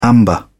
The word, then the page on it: amber: British English